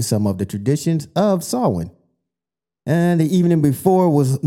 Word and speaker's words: Samhain: So the traditional origin, originated, god dang, with the ancient Celtic festival, and it's called Samhain, pronounced Samhain, but it looks crazy.